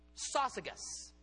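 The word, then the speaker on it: Syzygus: You would pronounce it Sosigas. I remember that because it's kind of like sausages with a hard G.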